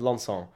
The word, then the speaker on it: Lanson: Are you scoffing at my pronunciation of Lanson? – Yeah. – It's how it's said.